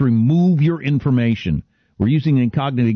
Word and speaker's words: Incogni: They can't scam you if they can't find you with the help of Incogni. Now, it's a lot like the word incognito. It's just shorter. It's spelled I-N-C-O-G-N-I, Incogni.